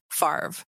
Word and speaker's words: Favre: His name is pronounced Brett Favre.